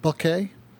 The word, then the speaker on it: bokeh: Bokeh. – I hear people say bokeh, which sounds lovely, but I think it's pronounced bokeh.